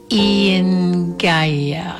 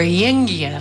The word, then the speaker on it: Eingya: That release was called Ian Gaia, I think. It's spelled E-I-N-G-Y-A, Ian Gaia. Or Yingya, take it as you will.